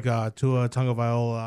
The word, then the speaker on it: Tua Tagovailoa: Well, I really like Tua Tagovailoa out of Alabama, the guy that replaced...